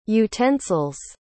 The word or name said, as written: utensil's